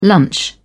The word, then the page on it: lunch: British English